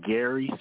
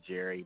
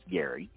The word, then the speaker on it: Gerry: It is pronounced Gary Smoot. It's spelled G-E-R-R-Y. It looks like you want to say Jerry, but it's actually pronounced Gary. So it is Gary Smoot indeed.